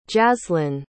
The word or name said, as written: jaslynn